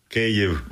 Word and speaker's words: Kyiv: It's Kyiv. Is that how you say it? The capital? – Kyiv.